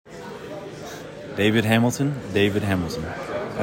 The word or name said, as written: David Hamilton